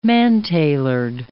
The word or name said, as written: man-tailored